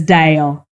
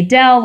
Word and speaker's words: Dale: He covers Bravo, and he always says Dale. And because people have teased the way that I say his name, because I say Dell, like Microsoft Dell, I am emphasizing the Dale in all of this.